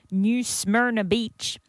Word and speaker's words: New Smyrna Beach: Do you have to say it like that? Do you? – Well, I feel like that's how you New Smyrna. Yeah, I feel like that's how it's said.